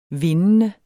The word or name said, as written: vindende